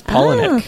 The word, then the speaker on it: Palahniuk: No, it's Palahniuk. Oh. – Palahniuk.